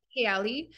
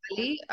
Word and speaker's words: Kayali: Yeah, I mean, I call it Kayali. In Arabic, it's Kayali, but I feel like that sounds a bit harsh, so I like to say Kayali.